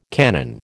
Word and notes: canon: American English

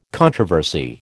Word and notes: controversy: American English

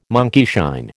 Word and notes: monkeyshine: American English

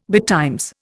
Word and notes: betimes: Indian

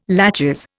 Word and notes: laches: Indian

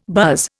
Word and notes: buzz: Indian

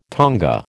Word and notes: tonga: American English

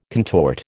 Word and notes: contort: American English

